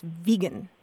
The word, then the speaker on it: vegan: And he insisted that the correct pronunciation was vegan.